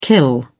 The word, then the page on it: kill: British English